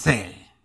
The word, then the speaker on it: Pretzel: Eso, la T y Pretzel. La Z. La T pegada a la Z. – Pretzel. – Yes. – Pretzel.